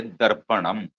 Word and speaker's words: darpanam: The South Indian has got a, especially Kerala and Tamil Nadu, we add am to that. So for mirror, in Malayalam we say darpanam. Kannada will say darpana.